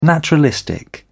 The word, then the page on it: naturalistic: British English